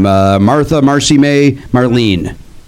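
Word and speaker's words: Martha Marcy May Marlene: And I saw, I get this name wrong each time, Martha, Marcy May, Marlene.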